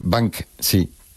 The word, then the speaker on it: Banksy: Pero bueno, como Banshee. Esas palabras. – Es Banksy.